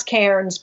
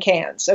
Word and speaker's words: Cairns: That's C-A-I-R-N-S, which we pronounce Cairns, but nobody in Australia says it that way. They say Cairns. Okay, nobody. – Cairns, like C-A-N-S.